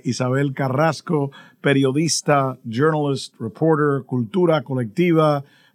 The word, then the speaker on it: Carrazco: I guess, you know, if we say her name the proper way, it's Carrasco, which is always fun to do because there's nothing like being able to roll your R's.